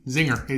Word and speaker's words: Xing'er: Pandas come from China, so they named him as Xing'er, X-I-N-G-E-R. And he was found by... – Like the cake. – Yeah, Xing'er. Because he is black and white. Xing'er.